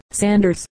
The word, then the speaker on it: Saunders: The name Ralph is pronounced as Rafe, as in rate, which I believe is Old English, and the names Saunders and Sanders are pronounced exactly alike, both being called Sanders.